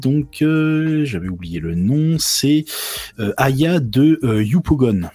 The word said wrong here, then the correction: Yopougon: Euh, Yopougon, pardon, euh, pour la prononciation.